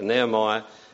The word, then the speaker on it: Nehemiah: But the name Nehemiah, pronounce it right, Nehemiah means comfort or consolation of Jehovah.